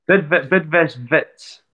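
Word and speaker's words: Bidvest Wits: So yeah, looking back to the 2021 season, so you joined us from, and I'm going to totally butcher this pronunciation, Bidvest? You're going to have to correct me on that one. Sorry. Is that right? – Bidvest Vits. – You got it good.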